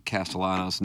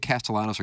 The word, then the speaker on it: Castellanos: I like the silent L's. Castellanos. – Castellano. Well, the you double L in Spanish Olavable becomes a Y.